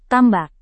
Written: tambak

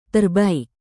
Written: terbaik

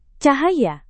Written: cahaya

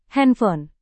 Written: handphone

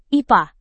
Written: ipa

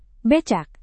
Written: becak

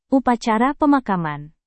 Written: upacara pemakaman

